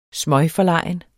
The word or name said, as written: smøgforlegen